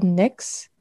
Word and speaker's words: Knix: A popular brand for the underwear one is called NYX, it's spelled KNIX. I think that's how you pronounce it.